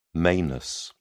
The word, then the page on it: manus: British English